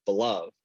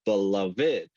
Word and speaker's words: beloved: It's a word that when I was younger and when I read it as beloved, and it didn't hit me that hard. But when I began to understand that there's actually three syllables in the word, it's beloved.